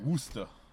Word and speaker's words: Worcester: But that's, like, it's spelled, like... – Like the sauce? – Worcestershire sauce? – Yeah, like Worcestershire. – Worcestershire. But it's Worcester.